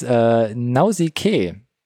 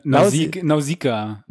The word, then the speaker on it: Nausicaä: Und einer seiner ersten Einträge so in seiner Vita ist Nausicaä. Nee, jetzt habe ich schon wieder falsch gesagt. – Nausicaä.